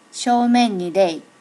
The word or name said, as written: shomen ni rei